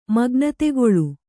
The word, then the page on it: ಮಗ್ನತೆಗೊಳು: Kannada